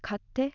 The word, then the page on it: katte: Japanese; aussage